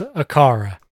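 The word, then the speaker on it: Aqara: And then they finally say it's pronounced Acara.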